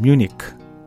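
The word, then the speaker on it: München: 미국에서는 뮤니크 뮤닉이라고 얘기하고 독일에서는 뮌헨, 뮌헨 이렇게 얘기해요. 세 나라 말이 다 틀려요.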